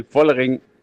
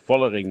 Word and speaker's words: Vollering: Vollering zwischen F und V. Vollering.